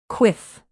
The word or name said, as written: quiff